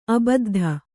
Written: ಆಬದ್ಧ

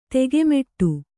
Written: ತೆಗೆಮೆಟ್ಟು

